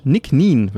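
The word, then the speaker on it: Nc'nean: Heute sprechen wir über Whisky, über den guten Nick Nien, wird er ausgesprochen, Organic Single Malt Scotch Whisky.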